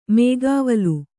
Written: ಮೈಗಾವಲು

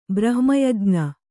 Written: ಬ್ರಹ್ಮಯಜ್ಞ

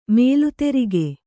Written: ಮಲೆದುರ್ಗ

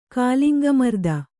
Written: ಕಾಲಿಂಗಮರ್ದನ

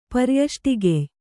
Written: ಪರ್ಯಷ್ಟಿಗೆಯ್

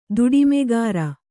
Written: ದುಡಿಮೆಗಾರ

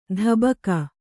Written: ಧಬಕ

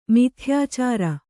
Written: ಮಿಥ್ಯಾಚಾರ

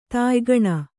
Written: ತಾಯ್ಗಣ

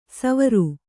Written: ಸೌವೀರ

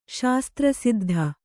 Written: ಶಾಸ್ತ್ರಸಿದ್ಧ

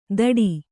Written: ಥಡಿ

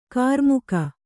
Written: ಕರಿಮುಖ

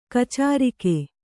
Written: ಕಾಚರಿಕೆ

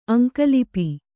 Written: ಅಂಕಲಿಪಿ

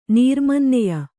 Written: ನೀರ್ಮನ್ನೆಯ

